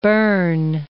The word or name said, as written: Bern